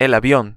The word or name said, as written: el avion